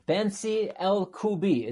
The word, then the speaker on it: Bentzi Elkouby: We are here with Bentzi Elkouby.